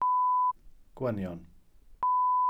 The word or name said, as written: gwynion